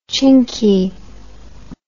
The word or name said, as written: chinky